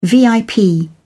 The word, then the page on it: vip: British English